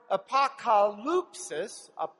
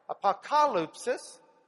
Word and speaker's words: apokalypsis: It comes from a Greek word, apokalypsis, emphasis is on the ah there, apokalypsis, which if we translate it, it means an unveiling, a disclosing, a removing of a cover.